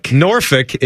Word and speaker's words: Norfolk: You would probably see the town in the Tidewater area of Virginia and just say Norfolk, right? – Norfolk. – Norfolk is how you say it, but you would just say Norfolk.